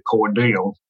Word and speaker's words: Cordell: And, you know, down here at the South, we pronounce things differently. It's actually Cordell.